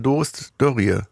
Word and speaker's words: Doris Dörrie: Äh, ja, du hast den Film gemacht mit äh, Doris Dörrie.